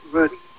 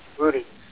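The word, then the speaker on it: Rudie: And the name is Rudy.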